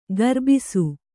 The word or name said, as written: ಕರ್ಬಸು